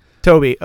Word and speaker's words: Tobe: T-O-B-E. – I've always heard Toby. – Toby.